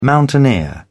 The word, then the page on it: mountaineer: British English